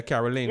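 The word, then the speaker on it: Carolyn: Yeah, Caroline.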